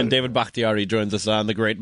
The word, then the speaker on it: Bakhtiari: David Bakhtiari.